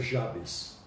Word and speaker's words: Jabes: Ela não tem, é, não é muito comentada é, a oração de Jabes, alguns falam Jabes, eu vou usar a pronúncia Jabes.